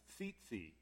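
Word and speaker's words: tzitzit: And the Hebrew word for tassels, try to pronounce that, it's tzitzi, okay? It's the Hebrew word tzitzi.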